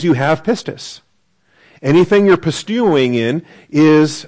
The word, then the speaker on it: pistis: That's the verb, P-I-S-T-E-U-O, long O with the omega. And then pistis. P-I-S-T-I-S.